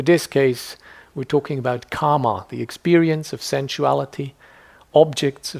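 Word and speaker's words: kāma: There is kama with a short A, has meaning action, and there is kama meaning sensuality.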